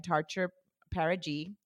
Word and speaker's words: TarcherPerigee: And she is the assistant director of marketing for Avery Books and Tarcher Paragy, yes.